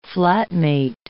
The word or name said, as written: flatmate